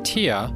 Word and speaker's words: tear: The noun tear, which is a drop of fluid from the eye, rhymes with the word deer. – She shed a tear when her cat died.